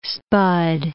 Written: spud

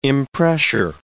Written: impressure

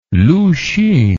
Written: lu hsun

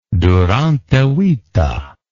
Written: durante vita

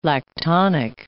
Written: lactonic

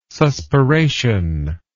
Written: suspiration

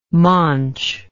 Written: maunche

